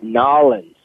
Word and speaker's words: New Orleans: In Nolens, that's how you say it. Nolens, right? – Nolens, that's all one, buddy.